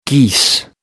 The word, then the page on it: geese: American English